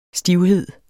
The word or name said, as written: stivhed